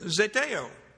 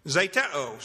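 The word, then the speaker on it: Zeteo (ζητέω): But in this verse 31, it's the simple word zeteo. Zeteo, sorry keep saying it wrong. Zeteo.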